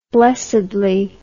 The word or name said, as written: blessedly